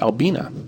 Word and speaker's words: Albina: And if you look up the dictionary, Wikipedia, anywhere, you look up the female name, Albina, and how to pronounce it, it's pronounced Albina. It comes from the Latin albinus, which also albino comes from.